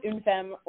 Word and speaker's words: une femme: We call it Unfem, or, we pronounce it une femme, or some French people call it une femme, but it means one woman in French.